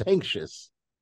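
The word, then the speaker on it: Sancious: David Sanchez. Is that what I said? – You said Sanchez twice. It's Sanctious.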